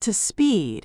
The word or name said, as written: to speed